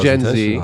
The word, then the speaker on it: Gen Z: Oh, I have faith in Gen Z.